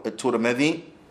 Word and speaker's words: Tirmidhi: This hadith has been collected by Abu Dawood and Tirmidhi, or Tirmidhi. His name could be pronounced both ways, Tirmidhi wa Tirmidhi.